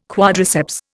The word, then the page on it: quadriceps: Indian